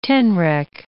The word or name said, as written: tenrec